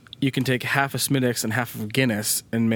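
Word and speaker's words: Smithwick's: It's pronounced Smittix. It's spelled Smith Wicks, which is exactly like Maniac Mansion.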